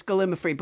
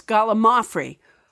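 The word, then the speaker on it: gallimaufry: It rolls off my tongue as gallimafry, but you're right. It's gallimafry.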